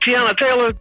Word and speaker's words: Teyana Taylor: and I know I'm gonna butcher her name, but it's the girl from what was it, Teyana Taylor? – Hey, that's how you say it.